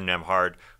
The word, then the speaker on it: Nembhard: Andrew Nembhard, who's got that silent B in his last name in between Nem and Hard.